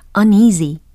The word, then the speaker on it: uneasy: uneasy. uneasy. 예. 끝소리가 z 이렇게 끝납니다. z. 어, g 아니에요. uneasy.